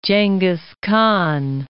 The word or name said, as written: Genghis Khan